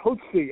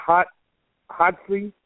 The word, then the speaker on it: Hotze: Spell it Hotze, H-O-T-Z-E. Like you could say Hotze, Hotze. Long O.